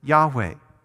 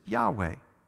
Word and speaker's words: Yahweh: Hebrew scholars don't even know how to pronounce it. The best we can come up with is Yahweh.